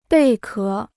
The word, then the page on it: 贝壳: bèi ké